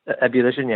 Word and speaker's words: Ebullition: When I first saw like Bloodlink or Ebullition, is that how you pronounce it? – Ebullition, yeah.